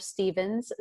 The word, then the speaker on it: Stevens: Stevens, spelled with a V.